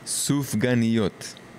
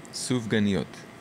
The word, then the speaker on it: Sufganiyot: Okay, I have trouble pronouncing Sufganiyot sometimes, so can you just say it a couple times so I make sure I get it right? – Sufganiyot. Sufganiyot. – Okay, very good. Yeah, I'm Jewish, but I'm embarrassed sometimes I don't pronounce it right. – You're doing a good job.